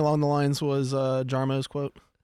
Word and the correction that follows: Jarmo: Did you just say Jarmo? – Yarmo.